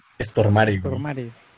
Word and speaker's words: Stormare: Bueno, el actor se llama Peter eh, Stormer, no sé cómo se pronuncia eso. Pero, Stormare.